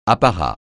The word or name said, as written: apparat